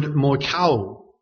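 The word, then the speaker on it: moichaō: The word he uses for adultery here is the Greek word moikao. Moikao. It's spelled M-O-I-C-H-A-O.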